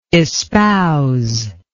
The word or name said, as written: espouser